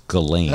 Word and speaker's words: Ghislaine: or Ghislaine as they're now saying, they keep changing the pronunciation, but it's Ghislaine.